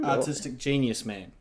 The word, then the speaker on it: Artistic Genius Man: Artistic Genius Man. – Is that... – The emphasis must be on the genius in between, because everyone knows that I'm a man, so it's Artistic Genius Man.